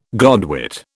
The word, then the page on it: godwit: British English